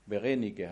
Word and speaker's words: Berenike: ich hoffe, ich lese es richtig, Berenike, oder? Berenike? Eigentlich Bernice. Das lässt sich besser auf Französisch aussprechen. Berenike, hast du gesagt, spricht man das aus.